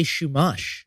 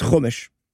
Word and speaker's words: chumash: What is a shumash? – Nope. It is chumash.